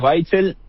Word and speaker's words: Waitzel: Me pueden seguir en LinkedIn, Matías Weitzel, mi apellido se escribe WAITZEL, o en Instagram, @ATinversiones.